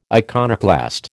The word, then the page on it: iconoclast: American English